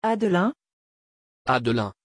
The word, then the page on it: adelin: French